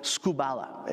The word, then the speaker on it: skubala: Now, the original word in the original text is actually a word called skubala. It's spelled S-K-U-B-A-L-A.